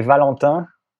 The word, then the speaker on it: Valentin: but how do I say it, Valentin? – You say Valentin, which is difficult because those vowels don't exist in the English language.